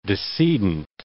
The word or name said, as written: decedent